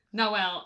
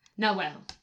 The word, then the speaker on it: Nahuel: Nahuel. Yes. – I don't know how to pronounce that. That was my... – Nahuel.